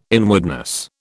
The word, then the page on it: inwardness: British English